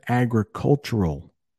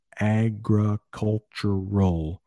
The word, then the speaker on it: agricultural: Agricultural. That L sound at the end is a tough one.